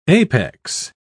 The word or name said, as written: apex